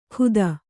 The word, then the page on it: ಖುದ: khuda